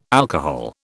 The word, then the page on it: alcohol: British English